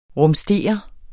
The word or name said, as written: RUMSTER